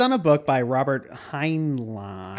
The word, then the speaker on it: Heinlein: I didn't know how to pronounce it when I was looking at it. Yeah, Heinlein. Because it's L-E-I-N, right? Or is it L-I-E-N at the last? – Well, it's like H-E-I-N-L-E-I-N. – Right. – Heinlein.